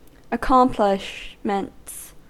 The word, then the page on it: accomplishments: American English